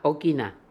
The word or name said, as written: Okina